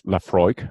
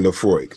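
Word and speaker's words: Laphroaig: Is it Lefrog? I know I'm butchering that name. I know. – Well, I'm not completely confident on that one as well. I think it's Lefroig. – Okay, Lefroig.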